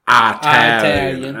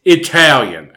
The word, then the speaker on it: Italian: My grandmother would pronounce it Italian. And one night I had finally had enough. And I went, Granny, it's Italian.